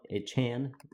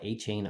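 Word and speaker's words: Achane: I mean, I like A-Chain a whole lot better, but he asked to be pronounced A-Chan, so I'm going to be respectful and call him A-Chan.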